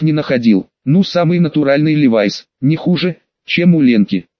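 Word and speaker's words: Levi's: И Левис, и Левайс, что ближе к оригиналу, и Леви Страус, и Леви Стросс, по имени отца-основателя компании, которого правильнее было бы именовать Левай Страус.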